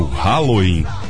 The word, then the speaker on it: Halloween: O Halloween. Não errei a pronúncia, não. É isso mesmo. R-A-L-O-I-N.